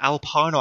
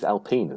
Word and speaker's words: Alpine: Is it Alpine or Alpine? – It's Alpine, isn't it? – I have no idea. But we'll go with that because you know that we love our pronunciations here at the NRF1 podcast, as is proven by Renault driver Daniel Ricciardo. But, yeah, Renault, Alpine, Alpine. We'll go with Alpine. – Alpine, yeah, with a French zhuzh on it.